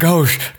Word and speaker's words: gauche: Yeah, I mean, and we are not gauche. Gosh, how do you pronounce that word even? – Gauche.